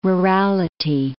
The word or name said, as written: rurality